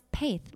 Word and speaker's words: Paith: And he's like, it's pronounced Paith. Like Paith. Have faith in Paith.